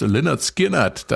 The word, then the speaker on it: Lynyrd Skynyrd: Wir feiern nämlich heute das Jubiläum 50 Jahre Pronounced Leonard Skinnert. Das Debütalbum der Südstaaten-Rocker kam nämlich am 13. August 1973 raus. Ein Debütalbum, das als Titel erstmal den Namen der Band in Lautschrift erklärt.